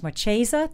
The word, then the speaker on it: Marchesa: She's a fashion designer, and her name is Marchesa. Did I say that correctly, Gary? – Yeah, you did.